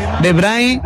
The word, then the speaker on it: De Bruyne: Yo sé que para el mundo del fútbol está un poquito más inyectado el tema de Bruyne o de Bruin, pero De Bruyne es la pronunciación correcta o es lo que él dice.